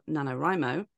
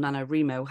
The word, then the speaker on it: NaNoWriMo: which is obviously the month before NaNoWrimo or NaNoWrimo. How do you say it? Am I saying it? – I say NaNoWrimo. The world says NaNoWrimo.